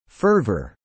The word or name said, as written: fervor